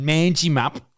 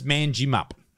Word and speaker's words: Manjimup: I was pissing off the whole town because I kept going, oh, fucking Manjimup. Stupid name. – I still don't think that's how it's pronounced. – It's Manjimup. Manjimup. Mangy, they call it.